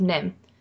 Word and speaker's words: NIMH: But it's based on a book called Mrs. Frisbee and the Rats of Nim. And Nim is spelled N-I-M-H, and it stands for National Institute of Mental Health.